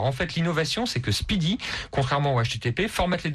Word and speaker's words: SPDY: Et ça s'écrit S-P-D-Y. Hein. – C'est ça, mais ça se prononce Speedy.